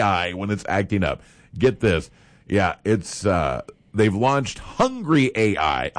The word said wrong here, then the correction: Hungr.ai: Hunger AI, yeah, that's what it's called.